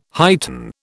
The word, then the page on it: heighten: British English